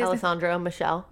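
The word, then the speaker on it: Alessandro Michele: Alexandre Michel. Is that how you say it? – Alessandro Michelle.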